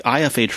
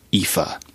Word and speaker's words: IFA: That's pronounced the IFA trade show in Berlin, is pronounced IFA.